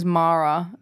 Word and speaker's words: Maara: Her name is Mara. It's spelled M-A-A-R-A.